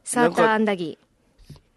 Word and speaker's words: サーターアンダギー: サーターアンダギー、サーターアンダギー、サーターアンダギー。 – これ討論したいですね。 – ね。 – サーターアンダギー。 – サーターアンダギー。 – ん？あれ？ – サーターアンダギー？ – いや、それは。 – え、どういうこと？イントネーション、ね、イントネーション。サーターアンダギーだよ。